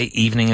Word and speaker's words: evening: Now, say evening again. – Evening. – It's like Eve. It's two syllables. Evening. Ning. Like Adam and Eve.